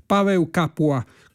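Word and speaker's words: Paweł Kapuła: diretta da Pavel Kapula, così sarebbe la pronuncia più corretta, Pavel Kapula è scritto, direttore polacco, classe 1992, al debutto sul podio dell'orchestra scozzese.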